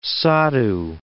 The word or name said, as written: saddhu